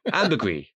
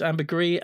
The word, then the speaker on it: ambergris: It's ambergris, ambergris, ambergris. Ambergris. Because I kind of learnt French at school, I tend to see words like that and pronounce them in the French way, gris, but I suppose... – In the French style. – It would be ambergris.